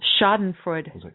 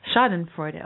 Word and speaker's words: Schadenfreude: Schadenfreude. – Okay. – Schadenfreude.